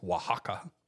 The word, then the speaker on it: Oaxaca: In the southern Mexican state of, I know how to pronounce this, Oaxaca, which it starts with an O, by the way.